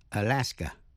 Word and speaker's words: Alaska: Alaska có hai chữ A ở đầu và cuối, đọc là Alaska, Alaska.